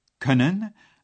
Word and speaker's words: können: Voici un exemple avec pouvoir, können, à la troisième personne du singulier. Le haut tréma prononcé e est remplacé par un o.